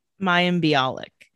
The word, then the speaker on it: Mayim Bialik: Mayim Bialik.